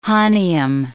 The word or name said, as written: hahnium